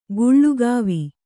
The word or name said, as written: ಗುಳಕವ್ವ